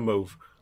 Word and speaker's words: mauve: I've never heard of mauve. – Do you mean M-A-U-V? – E. – Isn't it? – E, sorry, yeah. Is that how you really pronounce that? I always thought it was mauve.